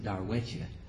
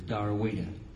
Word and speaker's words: Darwettia: Her name, she's got an old Choctaw Indian name, spelled D-A-R-W-E-T-T-I-A. The Indians pronounce it Darwetcha. Everyone knows her as Darwita.